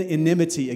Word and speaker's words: enmity: I tried to say it right earlier. I couldn't get it right. It's in enmity against your spirit.